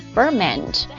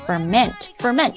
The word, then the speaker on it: ferment: 发酵 ferment，当然它有两个读音呢、啊、作为名词的时候它读作 ferment，那作为动词的时候它读作 ferment 就是发酵的意思。